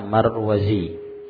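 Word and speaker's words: Al-Marwazi: Kalau sebelum ya itu ada za, ya, maka bacanya Marwazi, Zai, ya, dari Marwah. Adapun kalau Zha, ha, maka bacanya Al-Marruzi, dari Marrud. Nah, Wulida sanata 64 ba'dal mi'ah min al-hijrah, dilahirkan tahun 164 Hijriah fi Marwah, di Marwah.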